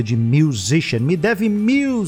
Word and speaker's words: musician: Musician, como a gente fala no dia a dia mesmo.